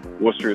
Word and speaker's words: Worcester: My name is Nathan Worcester. Worcester is spelled W-O-R-C-E-S-T-E-R on the Epoch Times website.